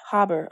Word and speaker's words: Haber: And so Fritz Haber, is that how you say his name? Fritz? Haber, okay, invented a synthetic fertilizer and pesticides for use in a gas chamber.